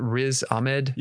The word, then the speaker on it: Riz Ahmed: But how do you say it? Riz Ahmed? – Yeah.